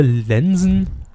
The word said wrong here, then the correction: Lenzen: Ne, Lenzen wird das dann wohl ausgesprochen, ne? – Ja, ja.